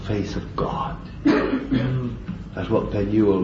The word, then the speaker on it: Penuel: That's the way to pronounce it. Emphasis on the middle word, the middle letter, Penuel.